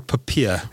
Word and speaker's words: POPIA: Advocate Tlakula, is it Popia or Popia? – Popia. – Popia. – Yes.